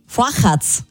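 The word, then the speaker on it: Fochaz: Fochatz spricht man es aus, ein zopfähnlicher Wecken mit Anis, den man aber bekannt mit Osterschinken und Creme essen kann.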